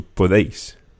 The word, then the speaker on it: podéis: And just one thing when you're pronouncing that vosotros form, the podéis, the E and the I of podéis kind of run together. There's not really three syllables in there, podéis, but you run the deis together, so podéis.